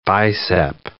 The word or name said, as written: bicep